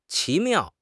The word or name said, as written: 奇妙